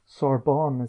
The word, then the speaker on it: Sorbonne: So you're writing a book just now, and you just went to the Sorbonne.